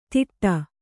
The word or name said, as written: ಧಿಟ್ಟ